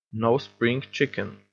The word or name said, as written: no spring chicken